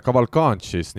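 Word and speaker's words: Cavalcanti: Gabriel Cavalcanchis, nii nagu hääldama peab, see uus mees, kes jaanuari kahekümne viiendal päeval Pärnu võistkonda saabus, on teinud nüüd Pärnu ridades kolm mängu.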